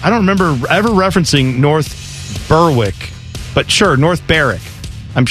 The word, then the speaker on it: North Berwick: My tool of the week is you for pronouncing North Berwick as North Berwick. It's actually pronounced North Berwick. The W is silent.